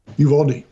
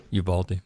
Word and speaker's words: Uvalde: How do you pronounce the name of the city? Uvalde? – Uvalde. – Uvalde. That the shooter... – I'll get corrected on that, but I'm pretty sure, because I listened to it on Books on Tape, so I'm pretty sure that's how you say it.